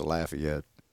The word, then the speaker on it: Lafayette: A fact check you. Just a fact check. It's Lafayette. – I said Lafayette. – No, you said it wrong again. – I didn't say Lafitte, fucker.